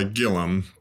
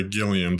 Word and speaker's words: Gilliam: Uh, the family calls it goes by Gillum, but I generally say Gilliam to anyone who might have to spell it. So either way works.